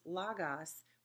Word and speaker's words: logos: It's L-O-G-O-S. It looks like logos. And anytime someone says it that way, I cringe a little bit because I'm really a stickler on pronunciation. So say it with me. Logos.